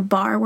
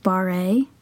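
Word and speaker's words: barre: And I've been doing the bar workout. No, it's not pronounced bar-a. And I've been going every day since April 1st. – That's good to know, because I definitely read it bar-a.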